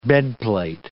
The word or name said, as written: bedplate